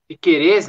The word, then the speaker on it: Piquerez: É, o seguinte, estamos por detalhes de anunciar Rocking Pekere Piquerez, né? O Jota estava ensinando ontem como é a pronúncia.